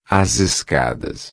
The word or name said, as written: escadas